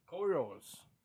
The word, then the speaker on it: Coriolis: Did I say that right? – Coriolis. – Coriolis. – Still somehow saying it wrong. – Coriolis. – Coriolis. Cori. – Cori. – Olis. – Olis. – Put it together. – Coriolis. – There you go.